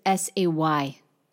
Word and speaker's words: c'est: So that's that's spelled just as S A Y in English, say.